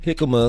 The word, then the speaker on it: jicama: Jicama is like a root veg. It's kind of across like a potato or apple. – Okay. – And it's spelled J-I-C-A-M-A, but the J is silent.